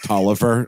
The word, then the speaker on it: Taliaferro: We've got one called, this looks like on paper, Taliaferro, but it's apparently pronounced Tolliver or something like that.